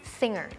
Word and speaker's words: Singer: จริงๆแล้วต้องออกว่า The Mask Singer เราจะไม่ออกเสียงเก้อเราจะออกเสียง Singer จะมีคำในภาษาอังกฤษหลายๆคำที่เป็น NGER Inger แต่ว่ามันออกเงอร์ไม่ออกเกอร์